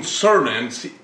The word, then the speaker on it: Cernan: Gene Cernan, C E R N A N.